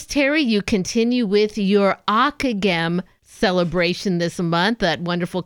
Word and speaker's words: Ockeghem: His name, by the way, is spelled O-C-K-E-G-H-E-M, Akagem.